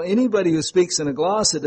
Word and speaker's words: glossa: So, he who speaks in a glossa, it was a known language, G-L-O-S-S-A is how we pronounce it, does not speak to men but to God.